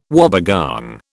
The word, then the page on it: wobbegong: British English